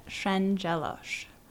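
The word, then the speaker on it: Szendzielorz: So my name's Justine Shenjelos. – Do you want to spell that for us? – Yep. It's S-Z-E-N-D-Z-I-E-L-O-R-Z.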